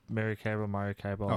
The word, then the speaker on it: Maracaibo: I don't think we're going to get into it too much, but that is Maracaibo. Did I even say that right? – Yeah, Maracaibo.